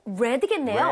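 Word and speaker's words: read: 처음에 read라고 했다가 뭐라 그랬죠? – 레드겠네요. – red라고 읽었어요. – 네.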